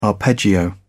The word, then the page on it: arpeggio: British English